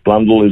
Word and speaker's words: Blundell: Any phonetic will tell you that should be Dell at the end, but that's just how we got branded, and it's Blundell is the correct pronunciation.